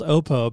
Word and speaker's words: Oppo: Oppo, I don't know. It's actually, now I know it's pronounced Oppo because they're actually are a sponsor of Wimbledon.